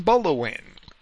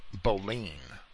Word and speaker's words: Boleyn: I should probably pronounce that Boleyn.